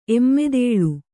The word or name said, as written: ಎಮ್ಮೆದೇಳು